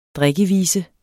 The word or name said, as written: drikkevise